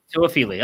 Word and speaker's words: Toefele: Help me out. – Lawrence Toefele. – Toefele. Okay, there we go. See, you told me that like 4 times, and I already forgot how to say it.